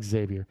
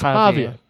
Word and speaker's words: Javier: So, in the case of Becerra, Javier Becerra, by the way, it's not Xavier. It's Javier Becerra.